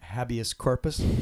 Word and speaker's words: habeas corpus: how do you pronounce it? Habeas corpus, habeas, habeas.